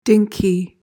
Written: dinky

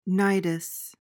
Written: nidus

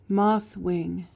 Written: moth-wing

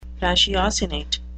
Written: ratiocinate

